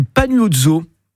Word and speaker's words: Panuozzo: C'est une mode qui est portée par le succès du Pagnozzo. Le Pagnozzo sur les réseaux sociaux. Mais qu'est-ce que c'est que le Pagnozzo? Ça s'écrit Panuozzo.